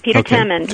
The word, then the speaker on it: Temin: One of them was, Peter Temin, is that how it's pronounced, by the way? – Yes, Peter Temin.